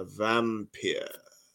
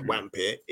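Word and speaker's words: Wampir: The W's are pronounced with a V. So it's not vampire, it's vampire. Oh, Bram loved that.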